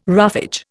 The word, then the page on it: roughage: Indian